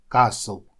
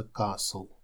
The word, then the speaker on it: castle: Итак, первое слово castle, замок, castle. Обратите внимание, что буква T здесь не читается в этом слове, просто castle.